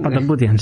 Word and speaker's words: Patapoutian: Patapoutian, eh, lo he dicho mal, bien. Patapoutian, sí. sí.